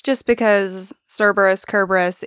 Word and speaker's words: Kerberos: So I had heard Kerberos more and more over the last few years because that is the way you pronounce it from the ancient Greek. It's spelled with a K.